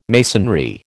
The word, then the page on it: masonry: American English